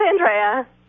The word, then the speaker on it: Andrea: Hi, this is Andrea from Durham, North Carolina. – Andrea, not Andrea. – No, it's Andrea. – That's the Italian pronunciation.